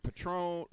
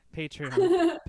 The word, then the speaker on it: Patreon: Your I'm going to say it wrong again, your Patron, all your stuff. – Patreon.